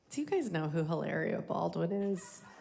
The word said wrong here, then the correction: Hilaria: I'm saying it wrong. I'm sorry. Hilaria Baldwin.